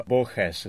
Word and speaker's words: Borges: The book you've mentioned is by the Argentine writer Borges, or Borges is more or less how they pronounce it in Spanish.